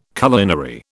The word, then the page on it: culinary: British English